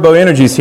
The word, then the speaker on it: Boanerges: So I like the word Boanerges here. That's how you say it. Well, that's how the guy on the audio Bible says it.